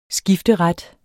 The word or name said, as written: skifteret